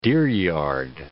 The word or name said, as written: deeryard